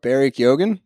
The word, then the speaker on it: Barry Keoghan: Side note, Barry Keoghan. – Yeah. – Did I say that right? – Yeah.